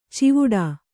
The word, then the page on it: ಚಿವುಡಾ: civuḍā